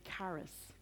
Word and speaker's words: charis: And the word here, the Greek word for favor here, is charis. We would pronounce it charis.